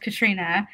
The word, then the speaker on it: Katarina: So, I grew up being called Katrina, but it's spelled like Katarina, I think, because the Spanish translation is Catalina with an L, like Catalina almost.